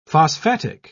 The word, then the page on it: phosphatic: British English